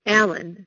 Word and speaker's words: Allyn: My middle name is Allen, spelled A-L-L-Y-N,